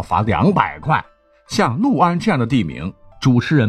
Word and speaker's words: 六安: 原来作为普通话标杆一级甲等的主播们，在播报安徽六安的新闻时，齐刷刷的竟然将六安读作六安，确实是一二三四五六的那个六啊。而在当地啊，甚至是我们以前的语文课本，在考一些易读错的字词读音的时候。六安一直是读作陆安啊，就有点像这个亳州不能念成亳州，漩涡的那个涡你得念成郭阳，不能念成涡阳。